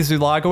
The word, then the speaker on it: Zuloaga: Yeah, we've got Lindsey Zuloaga with us.